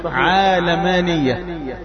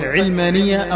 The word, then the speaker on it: علمانية: قد يقول قائل طالما أن النسبة إلى العالم فتنطق عالمانية، وهذا هو الصحيح، هذا هو النطق الصحيح عالمانية، لكن عندنا قواعد في اللغة العربية جعلت أهل العلم من المتخصصين ينطقوا هذه الكلمة بالكسر أو الفتح علمانية أو علمانية.